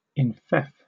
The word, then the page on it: enfeoff: British English